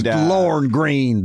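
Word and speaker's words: Lorne Greene: And it's Lorne Green. The E is decorative.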